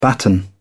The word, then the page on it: batten: British English